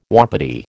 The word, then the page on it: wapiti: American English